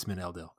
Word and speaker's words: Meneldil: It's Meneldil. – It is, in fact, Meneldil.